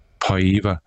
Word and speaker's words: Paiva: The bell kind of saved Papaya. How do you say that? How do you say his name? – Paiva, I think. – Paiva. Mm-hmm.